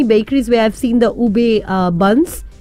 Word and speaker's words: Ube: Ube, U-B-E, इसे spell किया जाता है U-B-E, और pronounce किया जाता है as ube, जो एक naturally purple yam है.